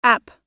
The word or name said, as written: app